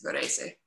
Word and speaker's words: Varese: No, towards, more towards Varese. – Varese, okay. – Yes, Varese.